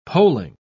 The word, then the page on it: polling: British English